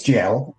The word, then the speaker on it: gaol: Is it, how do you pronounce that? G-A-O-L. – It's jail. – Okay. – And I just say the Americanized version of jail.